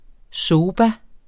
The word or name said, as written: soba